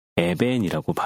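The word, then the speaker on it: 에벤이라고 (אבן): 그리고 히브리어로 돌이라고 하는 단어는 에벤이라고 발음을 해요.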